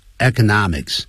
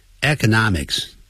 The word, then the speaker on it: economics: Then about politics 有关政治啊，and economics 这有两个发音，economics 啊，另外一个人念成 economics 啊，两个都可以，economics 经济学。